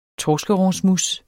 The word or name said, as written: torskerognsmousse